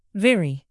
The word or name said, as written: vary